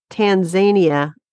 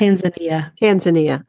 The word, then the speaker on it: Tanzania: By a guy named Walter von St. Paul Hilaire, and that's where they get the name St. Paulia ionantha, in Kenya and Tanzania in Africa. – Tanzania. – I knew I'd said that wrong.